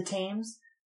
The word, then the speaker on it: Thames: And we see the Thames. Is that how you pronounce it? – Yes.